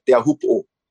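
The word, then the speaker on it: Teahupo (Teahupoʻo): People say Taps. Are we going to surf Taps? And I always say, no, we go surf Taapuna. I go surf Teahupo. That's the real pronunciation, you know.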